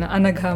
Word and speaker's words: Anagha: It's a pretty good pronunciation, Anagha Madhusudanan.